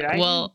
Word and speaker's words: Smyrna: Hopefully, am I saying that right? Smyrna? I've never heard of that part of Georgia. – You see. – Smyrna. It's Smyrna.